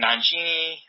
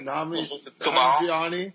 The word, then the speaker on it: Nanjiani: And like Kumail and Nanjiani, I'm sorry, I butcher your name. – Nanjiani.